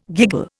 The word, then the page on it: giggle: Indian